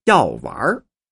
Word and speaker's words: 药丸儿: 当然，有些北京话不加儿化韵，是用来的发音就会引起许多误会，比如像药丸，其发音是要药丸。要完，那就是要快死了。药还没吃呢，人就要完了，岂不是找挨骂吗？所以一定要说药丸儿。